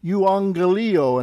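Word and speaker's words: euangelio (εὐαγγελίῳ): Martus, gar, mu, estin, ho, theos, ho, latruo, en, to, plumati, mu, en, to, euangelio. Make sure you get that accent on the last syllable, euangelio, and again the double gamma is NG. Tu huiu autu hos adialeptos, mneon, that's mneon, you get your mu nu together, mneon, homon, poiumai.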